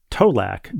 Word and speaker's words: Tolac: As of this note, one Tolac delivery, he, pre- pronounces, he provides a pronunciation key, T O E L A C K, but but spelled out in all caps T O L A C.